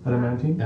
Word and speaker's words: adamantine: Okay, so I've got an, I don't know how to pronounce it, but an adamantine? – Adamantine? – Adamantine. – Oh, adamantine. – Yes.